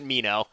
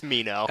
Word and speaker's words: Minho: And he's like, no, you just pronounce it Mino. And they're like, well, that doesn't really make any sense. Why is it spelled Minho then? Nah, it's fine. It's Mino.